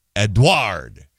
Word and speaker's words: Edward: Crease, so they're going K-R-E-E-S with an emphasis on the middle syllable. Cristobal. It's Tobal. Cristobal. Edward. Edward is the emphasis there.